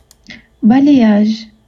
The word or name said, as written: Babylight